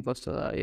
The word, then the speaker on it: Oyinkansola: It's Fawehinmi Oyinkansola, yes. Foza is different from Fawehinmi Oyinkansola.